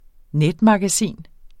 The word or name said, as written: netmagasin